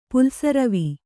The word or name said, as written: ಪುಲ್ಸರವಿ